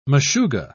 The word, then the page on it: meshuga: British English